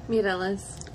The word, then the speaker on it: Mireles: Mireles. – Ashley M-I-R-E-L-E-S.